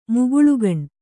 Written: ಮುಗುಳುಗಣ್

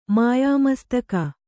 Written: ಮಾಯಾಮಸ್ತಕ

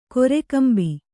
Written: ಕೊರೆಕಂಬಿ

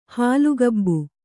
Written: ಹುಲ್ಲುಗಾಬು

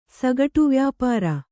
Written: ಸಗಟುವ್ಯಾಪಾರ

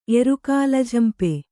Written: ಎರುಕಾಲಝಂಪೆ

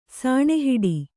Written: ಸುಣ್ಣಹೊಡೆ

